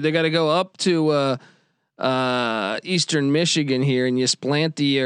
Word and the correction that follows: Ypsilanti: or whatever. How do I say that? – Ypsilanti, Michigan.